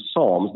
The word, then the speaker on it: Psalms: And he says, now the book of Psalms is Psalms. The P is silent.